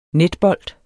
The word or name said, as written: netbold